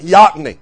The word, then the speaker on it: Yocona: There's a river near where I was raised called Yocona, Y-O-C-O-N-A. Now, we never pronounced it that way back home. We called it Yocony. You won't find Yocony on the map. It would be spelled Yocona.